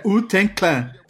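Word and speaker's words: Wu-Tang Clan: É que a, a, a pronúncia é U Ten Clan.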